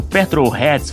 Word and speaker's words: petrolheads: Ah, Eduardo, você que também é um outro petrolheads, falei certo, eh, Valese? – Exatamente, tá, tá perfeito.